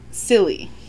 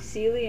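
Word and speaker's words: Seelie: And that's where it took the final step to becoming simple-minded, and the modern English silly.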